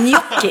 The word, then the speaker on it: gnocchi: Det är jättemånga som säger till exempel, nu ska vi göra gnocchi. Och det är gnocchi uttalas det. Och det är så här, ja men det är italienskt, det betyder öga på italienska. Så då borde man ju veta hur det uttalas liksom.